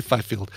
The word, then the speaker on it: Fifield: Let's say Fifield. – Sure. – F-I-F-I-E-L-D. It's like field with F-I at the beginning.